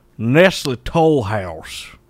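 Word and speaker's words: Nestlé Toll House: Why you say it like it's cuisine? – Nestle Tohal. – I believe the term you're looking for there, and let me translate to all the locals. Nestle Toll House.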